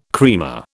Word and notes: creamer: British English

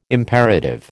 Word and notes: imperative: American English

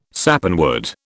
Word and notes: Sappanwood: British English

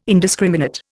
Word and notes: indiscriminate: Indian

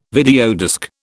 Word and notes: videodisc: British English

